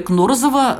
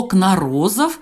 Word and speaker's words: Кнорозов: Вот, например, Юрия Кнорзова называют именно Кнорзов с ударением на первую букву О. Но некоторые люди возмутились этому и сказали, что на самом деле ударение на вторую букву О, Кнорозов, и приводили в пример э, Википедию там, и какие-то другие источники. Вы, Галина, как человек, который непосредственно лично общался с героем нашей программы, как э, вы Э, считаете, на какую букву все-таки ставить ударение? – Я, конечно, говорю Кнорозов, так как он сам мне представился,